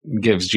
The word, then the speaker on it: Givz: That's how you pronounce it, right? – That's right. Gives GIVZ. Yep.